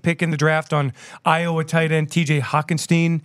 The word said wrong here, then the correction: Hockenson: mm-hmm. uh, who's a blocker, who's also. Hockenson. Hockenson, I'm sorry.